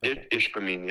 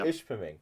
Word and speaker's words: Ishpeming: And Ishpeming, is that how it's pronounced? Or Ishpeming, yeah. – Ishpeming.